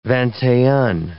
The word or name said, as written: vingt-et-un